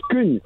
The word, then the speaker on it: Scone: It's actually from a place called Scoon. – Spoon. – Scoon. It's spelled scone. It's in like centre of Scotland.